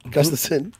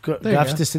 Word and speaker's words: Gustafsson: Gustafsson. – Gustafsson. That's it. Gustafsson. – I knew I said it right.